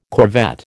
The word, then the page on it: corvette: American English